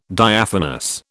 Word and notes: Diaphanous: British English

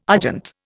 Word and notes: argent: Indian

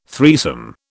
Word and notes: threesome: British English

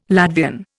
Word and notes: Latvian: Indian

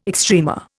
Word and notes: extrema: Indian

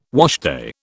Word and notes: washday: British English

